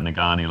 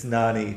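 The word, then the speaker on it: Ngani: It's Nani. Thanks. Pronounce his first name Nani Lamapi. I don't understand how with the G in it, but silent. The G is silent.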